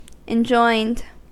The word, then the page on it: enjoined: American English